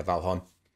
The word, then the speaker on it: Valheim: Yeah, Valheim.